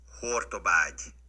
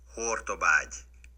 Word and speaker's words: Hortobágy: Uh, the correct pronunciation. This is Hortobágy. Hortobágy. Got it? – You heard it there.